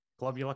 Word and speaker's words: Globular: I have seen in some of your research in the past, you've looked at, I hope I'm pronouncing this properly, it's either global or global clusters? – Globular clusters, yes. Globular clusters.